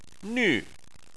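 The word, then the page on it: nv: tone 4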